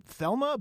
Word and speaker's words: Thelema: I think it's called Thelma, but it's spelled like Thelema is the way it's spelled.